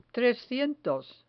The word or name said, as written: trescientos